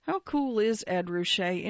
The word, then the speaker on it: Ruscha: Ruscha, to quote the business card he once passed around, which has marked on it pronounced Ruscha,